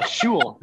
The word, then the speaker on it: Shewell: Hey, Shewell? How's it going? Yeah, Shewell.